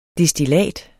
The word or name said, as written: DESTILLATERNES